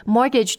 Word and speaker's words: mortgage: There is a T in the middle of this word, and that T is silent. Mortgage, mortgage. – 所以我们不说 mortgage，是 mortgage。 – Yes, it's also not mortgage.